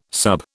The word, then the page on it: sub: British English